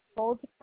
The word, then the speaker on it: Goldberg: You said your first name is Irma, I-R-M-A? – Yeah. – What is your last name? – Goldberg. – Can you spell that for me? – G-O-L-D-B-E-R-G.